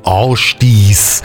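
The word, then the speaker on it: Ásdís: Ja, die Sängerin Astis kommt aus Island und eigentlich spricht man den Namen, ihren Vornamen Astis, auch isländisch aus. Also korrekt ausgesprochen müsste er natürlich heißen Ástis.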